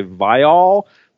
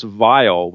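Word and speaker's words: Viall: You kind of went out of your way to say Vile, but it's typically pronounced Vile, which is, you know, I'm wondering if one of the next contestants on The Bachelorette is going to be Johnny Reprehensible.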